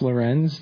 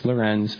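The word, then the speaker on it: Lorentz: My name is Max Lorenz. That's Lorenz. – Sorry. Yeah, the T is silent. They just threw that in there for fun. It's Max Lorenz, M-A-X, L-O-R-E-N-T-Z.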